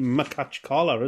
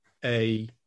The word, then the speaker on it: Makhachkala: And it's Anzhi Makhachkala, isn't it? – Right, Makhachkala.